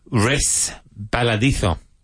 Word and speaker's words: resbaladizo: Resbaladizo.